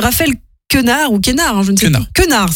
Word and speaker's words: Raphaël Quenard: Alors, autre premier long-métrage, d'ailleurs signé Michael Dichter, Les Trois Fantastiques, un drame mené par Emmanuel Berco et Raphaël Quenard, ça se prononce comme ça, c'est vrai.